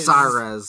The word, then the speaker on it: Cyrex: Right, the X at the end and they're pronouncing it Cyrez.